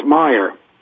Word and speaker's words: Meyer: Am I saying your name right, Meyer? – It's Meyer. – It's Meyer. You had the M-E-Y, so I wasn't sure.